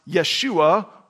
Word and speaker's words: Yeshua: In Hebrew, it's pronounced Yeshua or Joshua.